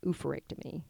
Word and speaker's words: oophorectomy: I had a oophorectomy.